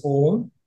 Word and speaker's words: Om: It's a Sanskrit word pronounced as Om.